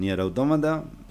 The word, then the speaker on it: Nier Automata: Eh, Nier, y si lo querés decir algunos dicen automata o automata, pero es automata para nosotros en ah, español.